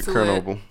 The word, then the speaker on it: Chernobyl: It's actually Chernobyl. – Is it Chernobyl? – Is that how they pronounce it? – It's Chernobyl.